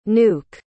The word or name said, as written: nuuk